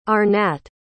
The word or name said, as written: Arnatt